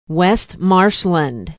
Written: west marshland

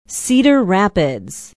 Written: cedar rapids

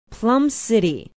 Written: Plum City

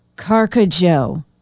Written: carcajou

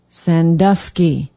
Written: Sandusky

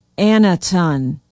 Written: annaton

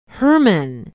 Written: herman

